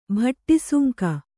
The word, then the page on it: ಭಟ್ಟಿಸುಂಕ: Kannada